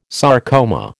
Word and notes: sarcoma: American English

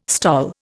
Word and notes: STOL: Indian

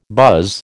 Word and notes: buzz: American English

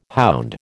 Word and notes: hound: American English

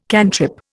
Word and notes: cantrip: Indian

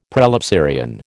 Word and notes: prelapsarian: American English

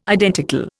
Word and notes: identical: Indian

